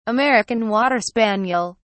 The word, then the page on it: American water spaniel: British English